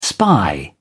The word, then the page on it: spy: British English